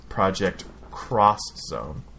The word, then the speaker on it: Project X Zone: And there was a video game that came out by, I think, Square, the Final Fantasy people, and it was called Project X Zone, but it's pronounced Project Cross Zone.